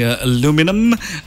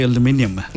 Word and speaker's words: aluminium: Americans say aluminum. The British say aluminium.